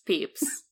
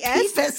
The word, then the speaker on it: Pepys: P-E-P-Y-S, peeps. – That's not peeps, that's peepis. That's peepies. That's peepis. – I'm telling you, it's pronounced peeps. – It's peepis. P-E-P-Y-S.